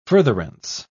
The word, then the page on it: furtherance: British English